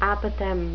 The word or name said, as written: apothegm